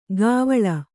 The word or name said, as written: ಗಾವಳ